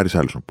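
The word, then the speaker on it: Richarlison: Είναι, λέει, σωστή προφορά Ριτσάρλισον. Πολύ ωραία να τον προφέρουν σωστά αυτοί που είναι στη χώρα του. Εμεί δεν είμαστε Βραζιλιάνοι. Δεν είμαστε Πορτόγαλοι, είμαστε μόνο κύτταροι. Πώ το λέει, Ριτσάρλισον. CH. Ριτσάρλισον. Τελειώνει εκεί.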